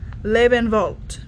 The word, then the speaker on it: Lebenwald: That's pronounced Lebenwald. Go ahead and say it. German is fun.